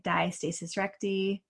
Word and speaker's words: diastasis recti: I say diastasis recti, diastasis recti, diastasis.